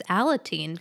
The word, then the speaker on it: Alateen: Is it pronounced Alateen? – Oh, yeah. The clue is adolescent support group, and the answer is Alateen.